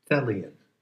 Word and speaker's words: Thelian: Put on the badge that says Thelian. T-H-E-L-I-O-N. And it rhymes with Hellion. And it must be true because, well, you know. Thelian.